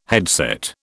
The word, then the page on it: headset: British English